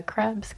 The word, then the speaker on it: Krebs: It's just my name, Amanda Krebs, K-R-E-B-S.